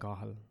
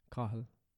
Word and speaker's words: Cathal: Like, so, I mean, you're not being racist, but you're certainly disrespecting Cahill. C-A-T-H-A-L. That's how you spell it. Cahill.